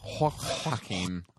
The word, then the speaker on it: Joaquin: It's Joaquin.